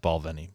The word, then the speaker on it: Balvenie: Okay, it is pronounced Balvenie, by the way.